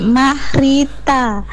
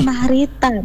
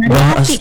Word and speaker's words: Mahrita: Tunggu dulu, bukan Mariati, Mahrita. Mahrita, bukan Mariati.